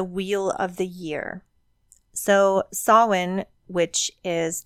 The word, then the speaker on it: Samhain: So Samhain is how you pronounce it. It is spelt, it looks like Samhain, the way it's spelled, S-I-A-M-I-A-N, I think is how it's spelled. But it is pronounced Samhain. That's the actual Gaelic Celtic pronunciation.